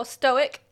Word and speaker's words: stoic: Stoic. – Yeah. – I was gonna say stoatic again. – Stoatic. – Because right. – there's apparently a silent A in there in my brain.